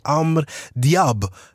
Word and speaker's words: Amr Diab: Ett sms står så här: tjo, någonting med Amr Diab. Uttalar jag det rätt eller fel? – Ja, bra.